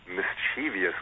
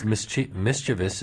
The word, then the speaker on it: mischievous: It's February doesn't bother me so much, and neither does often, but mischievous, which is not only a mispronunciation, but it's a outright mispronunciation. But because there's no I between after the V, I don't think. – No, it's a whole extra syllable.